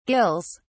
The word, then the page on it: gills: British English